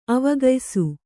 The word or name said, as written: ಅವಗಯಿಸು